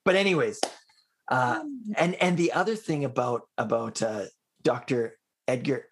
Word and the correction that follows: Edith Eger: how do I say her name again? – Edith Eger. – Edith Eger. Yeah.